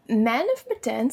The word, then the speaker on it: Man of Medan: Man of Medan stood out. That was pretty good. I hope I said that right. – Yes, no, that was spot on. That was perfect.